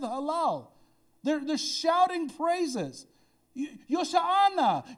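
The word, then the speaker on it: Hoshana: It's Yoshana, which we get Hosanna from. I know, we butcher it. We're like, Hosanna. Yoshana.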